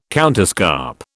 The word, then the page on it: counterscarp: British English